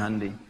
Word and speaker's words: Hyundai: Ya, Jepang bikin Honda, ya Korea bikin Hyundai, bacanya Hyundai.